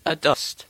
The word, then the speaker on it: adust: A-D-U-S-T. – So it sounds like, it's spelled like it sounds, adust. Yeah. Not aduster. – No. – No, adust. Not adust either, is it? – No.